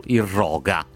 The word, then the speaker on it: irroga: Intanto, scusa, interrompo il giornale di risparmio per informarti che Daniela ci segnala che l'accento di irroga va su irroga.